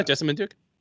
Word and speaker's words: Jessamyn Duke: Yeah, Jessamyn Duke.